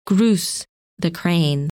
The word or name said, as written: Grus